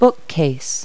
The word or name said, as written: bookcase